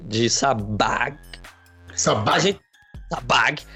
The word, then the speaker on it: Sabbagh: é, que, aliás, como é que fala o nome do Danilão? É Dan Sabag ou Sabá? – Danilo Sabag. Sabá é TH.